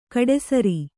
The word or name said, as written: ಕಡುಸಾರೆ